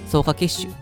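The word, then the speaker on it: 爪下血腫: はい、でこの爪の出血、まあ、ちなみにえ専門用語では爪の下の血の、ま塊と書いて草加血腫というふうに読む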